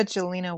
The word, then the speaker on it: Jolena: Sara Jolena Walcott.